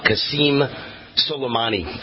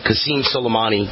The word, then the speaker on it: Qasem Soleimani: Let me get his name right. I always have to go back and look. It's General Qasem Soleimani. Qasem Soleimani.